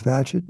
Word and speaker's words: Bagehot: Uh, so I learned how to pronounce his name as in Gadget, no small thing. I saw that the prior biography of Bagehot uh, had been produced as long ago as the 1950s.